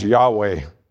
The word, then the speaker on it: Yahweh: Notice that the seraphim called him Lord, capital L, capital O-R-D, all capitals. It's the name often pronounced Yahweh, which is a sacred name of God.